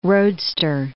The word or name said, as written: roadster